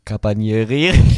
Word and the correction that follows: Carabinieri: Carabinieri. Carabinieri.